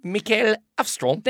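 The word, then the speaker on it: Mikael Håfström: And it is directed by Michael Hafstrom. – Mikael Hafstrom.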